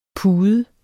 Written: PUDERNE

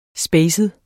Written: spacet

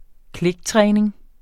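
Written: kliktræning

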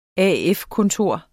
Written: AF-kontor

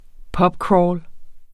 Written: pubcrawl

